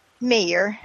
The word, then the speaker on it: Mayer: Is it Mayer or Meyer? Because I'm Canadian. – Mayer.